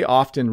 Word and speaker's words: often: Does the word often have a silent T? When you say often really quickly, so I often say it really quickly, the T becomes very, very silent and you hardly hear it.